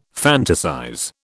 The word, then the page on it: fantasize: British English